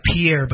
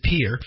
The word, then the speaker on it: Pierre: It looks like Pierre, but they pronounce it Pierre.